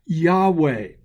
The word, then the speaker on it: Yahweh: Yud, He, Vav, He are the four Hebrew letters that are correctly, I believe, pronounced Yahweh.